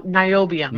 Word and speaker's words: niobium: Is it Neoplume? Did I say that right? – Say that again? – It says pure neobalm, NIOBLUM. – Oh, niobium.